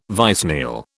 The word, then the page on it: vicennial: British English